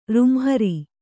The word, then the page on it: ರುಂಹರಿ: kannada